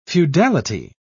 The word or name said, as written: feudality